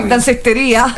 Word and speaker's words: Dansetteria: Come si pronuncia? Danseteria. Allora, siccome sono anni 90. – Dansetteria. – Esatto. – Dansetteria.